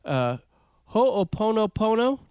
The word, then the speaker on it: Ho'oponopono: And then in February, for those who are subscribed to that, we're gonna have an episode coming out about the Ho'oponopono.